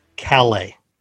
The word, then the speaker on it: Calais: and just pointing out that his last name is pronounced Calais, not Calais.